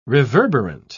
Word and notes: reverberant: British English

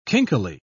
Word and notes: kinkily: British English

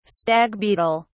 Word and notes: stag beetle: British English

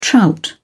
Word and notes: trout: British English

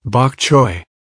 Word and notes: bok choy: American English

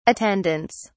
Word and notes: attendants: British English

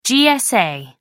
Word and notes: GSA: American English

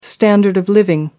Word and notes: standard of living: American English

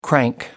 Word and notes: crank: American English

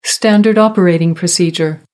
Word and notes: standard operating procedure: American English

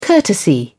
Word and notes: courtesy: British English

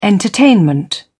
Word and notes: entertainment: British English

